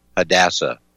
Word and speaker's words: Hadassah: It's called, I believe they pronounce it Hadassah, H-A-D-A-S-S-A-H, Hadassah.